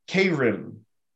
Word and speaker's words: cherem: The word accursed in the Hebrew is kerem. Kerem, it's spelled C-H-E-R-E-M, kerem.